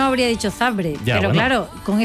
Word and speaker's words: Zabrze: ¿Por qué sabes que es Zabrze? ¿Por qué pronuncias porque lo sé. – Zabrze? – Porque es así.